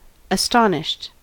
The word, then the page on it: astonished: American English